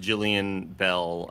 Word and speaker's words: Jillian Bell: I kind of forgot as Rob Riggle being kind of like the X Factor and against Jillian Bell.